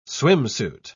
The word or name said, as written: swimsuit